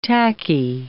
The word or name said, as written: tacky